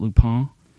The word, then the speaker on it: Lupin: It's actually French, so they would pronounce it Lupin, but it's a French show, but there's English dubbed, you can read the subtitles, but it's a pretty good dub.